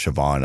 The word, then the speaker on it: Siobhan: Her name was Siobhan.